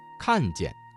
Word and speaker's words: 看见: 好，看见这个词中的见读成了轻声，读看见。